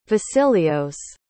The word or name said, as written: vasilios